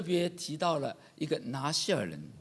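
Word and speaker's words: 拿细耳: 这拿西尔原来就是希伯来文的一个单词，它的读音就叫拿西尔。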